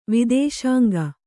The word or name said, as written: ವಿದೇಶಾಂಗ